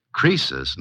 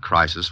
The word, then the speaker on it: Croesus: Crisis was a rich king. – Oh, don't you know, Suzy? Croesus, not Crisis, was a rich king.